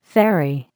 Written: ferry

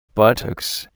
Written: buttocks

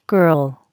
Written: girl

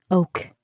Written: oak

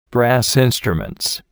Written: brass instruments

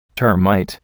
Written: termite

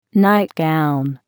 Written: nightgown

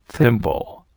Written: thimble

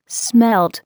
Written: smelt

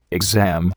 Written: exam

